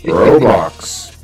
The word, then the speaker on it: Roblox: Roblox. It's pronounced Roblox.